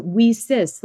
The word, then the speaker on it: WiCyS: And so we are Women in Cybersecurity, but we often go by our acronym, WSIS, and it's spelled W-I-C-Y-S. We pronounce it WSIS like we sisters because we're a global cyber sisterhood.